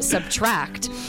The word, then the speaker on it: Subtract: Ed has announced his 6th studio album entitled and pronounced Subtract.